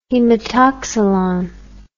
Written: haematoxylon

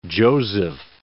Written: Joseph

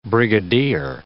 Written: brigadier

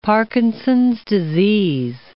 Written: Parkinson's disease

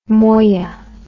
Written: moya

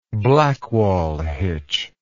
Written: blackwall hitch

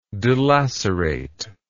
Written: dilacerate

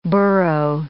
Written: borough

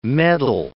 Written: meddle

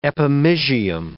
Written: epimysium

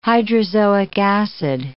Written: hydrazoic acid

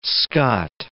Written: skat

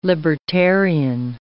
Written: libertarian

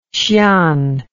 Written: xian

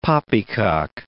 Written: poppycock

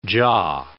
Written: Jah

